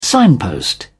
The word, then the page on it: signpost: British English